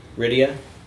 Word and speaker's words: Rydia: Yeah, I was just about to say, actually, the first, when you get Rydia, or Rydia? – Rydia. – Rydia? So it's like Lydia, but with an R.